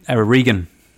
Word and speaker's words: Regan: Regan. He types that into the computer. How do you spell that? R-E-G-A-N.